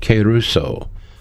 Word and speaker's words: kerusso: This is the word keruso, K-E-R-U-S-S-O. The E is a long E, so you could pronounce it keruso.